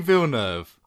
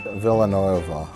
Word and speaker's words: Villeneuve: Denis Villeneuve.